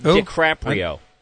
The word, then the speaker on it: DiCaprio: That last name is pronounced DiCaprio.